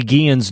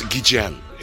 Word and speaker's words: Guillen: Apparently, we've all been saying Ozzie Guillen's name wrong after all these years. – Because if you ask Ozzie or Bob Costas, it's Guillen.